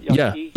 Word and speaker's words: Yaqui: Is that pronounced that right? – Yaqui? Yeah. Mm-hmm. Yeah. Yaqui.